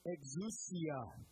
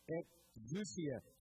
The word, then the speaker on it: exousia: Verse 7, the word for authority is exousia. Try that with me. Exousia. Fill it with more emphasis on the end.